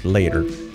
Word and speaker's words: later: La pronunciación, la, la, con la E. La es A. Later. La T entre vocales es una resuave. Later. Y la R luego. Later. La E como si no existiera.